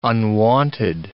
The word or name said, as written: unwonted